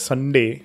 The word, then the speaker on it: sundae: I used to be like, Sunday, why is it like S-U-N-D-A-E? Is it like pronounced as Sunday? Actually, I got to know that it's pronounced as Sunday, like proper Sunday, like today.